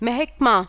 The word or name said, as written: महिकमा